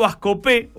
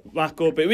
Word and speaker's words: Bascopé: Sí, está bien pronunciado. Es Bascopé, con acento en la E. – Bascopé.